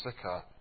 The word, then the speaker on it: Sychar: I don't know how you want to pronounce it. Sychar. I pronounce it Sychar.